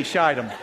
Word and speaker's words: Shittim: It shall forever be Shittim.